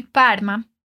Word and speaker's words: Parma: Lembra que aqui a pronúncia do R de Parma é como aquele R forte do gaúcho. Então me diz, como eu falo eu sou de Parma?